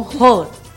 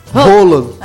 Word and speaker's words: Haaland: Eles pronunciam Roland. – Roland. – É, Roland.